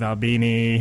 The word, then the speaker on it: Albini: His name rhymes with Gandolfini. – Oh, I guess it does, huh? That's right. Later on in the show, we're going to have none other than Mr. Steve Albini.